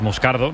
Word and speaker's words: Moscardo: Como bien ha pronunciado Unai, es Moscardo, brasileño, sí, sí.